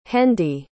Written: Hendy

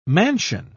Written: mansion

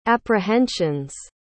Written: apprehension's

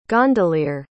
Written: gondoliere

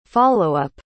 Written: followup

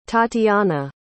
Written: tatianna

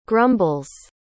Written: grumbles

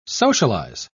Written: socialize

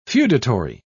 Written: feudatory

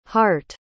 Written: harte

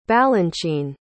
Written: balanchine

